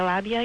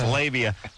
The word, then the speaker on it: labia: It's come to my attention through doing this show over the course of the years that a fair amount of this country pronounces labia labia. – Really? I think it's just emerald. – I hear it on this show. – Oh. – I do hear labia. – Oh. Well, there you go. For the record, labia. – I think it's labia is what you mean, right? – I've never heard it said. I've just read it. So. – See, it looks like it should be labia.